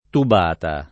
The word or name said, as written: tubata